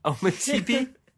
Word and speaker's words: Ometepe: The tribes are called Ometepe. Ometepe. Is that the pronunciation? – For the podcast, yes.